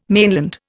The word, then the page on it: mainland: Indian